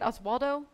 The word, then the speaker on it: Oswaldo: Am I saying that right? Oswaldo? – Yes.